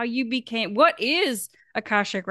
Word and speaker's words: Akashic: Her company is Ember Healing, and she is, I gotta say this right, an Akashic Records coach.